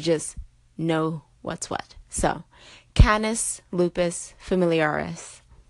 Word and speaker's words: Canis lupus familiaris: let you know that is Canis lupus familiaris, not Canis lupus familiaris. So Canis, like a soup can, instead of Canis, like a candy cane.